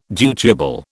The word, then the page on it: dutiable: British English